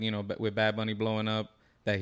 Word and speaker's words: Bad Bunny: So Bad Bunny.